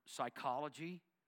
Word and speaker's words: psychology: In the word psychology, the P is silent.